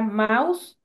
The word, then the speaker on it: Maus: Entonces, esta novela Maus, que se escribe así, M de mamá, AUS de sapo, como mouse, se suena como mouse, como ratón en inglés.